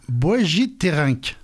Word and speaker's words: boegiterink: Et en bruxelleur, c'est-à-dire la langue de Bruxelles, c'est un boegiterink. – Ah, c'est très bien dit, je suis sûre voilà. De la prononciation.